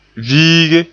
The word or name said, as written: viige